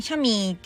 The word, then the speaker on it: chamois: こいつ、あの、英語だとですね、まあ、発音的にシャミーって言われるらしいんですけど、スペル全然違うんですね。